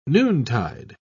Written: noontide

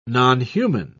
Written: nonhuman

